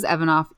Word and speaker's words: Evanoff: And the last name is Evanoff, EvanOff. It's like Evan off.